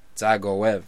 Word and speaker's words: Dzagoev: Zagovich. – That's terrible. – Gov? – Zagov? – Zagov? – The D is silent. – Zagov? – Zagov. – Zagov? – There you go.